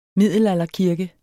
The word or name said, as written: middelalderkirke